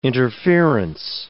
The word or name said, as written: interference